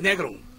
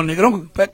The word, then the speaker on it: Negron: ya que se murió Chuck Negron. – Dios santo. Y es Negron. Parece, se escribe como como negro, con N, o sea, pero es Negron, no Negrón.